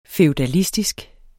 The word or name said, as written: feudalistisk